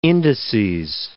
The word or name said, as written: indices